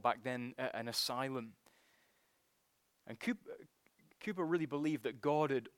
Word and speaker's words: Cowper: I opened my sermon last Sunday morning, didn't I, by quoting a few verses from William Cowper's great hymn, "God Moves in a Mysterious Way." And it is Cowper, by the way, not Cowper.